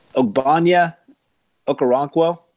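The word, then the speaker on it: Ogbonnia Okoronkwo: Ogbonya Okoronkwo. – You said it right. You did it.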